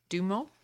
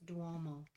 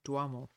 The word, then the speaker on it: Duomo: After my descent down the tower, a much less exhausting ordeal, I made my way over to the famous Duomo, the huge cathedral. Did I say that right? – I think it's Duomo. – Duomo.